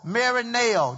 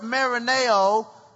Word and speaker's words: merimnao: This Greek word in the Greek, which is take no thought or take thought, is the word M-E-R-I-M-N-A-O, marineo. That's how I'm going to pronounce it. This word marineo in the Greek is the English words take thought or take no thought.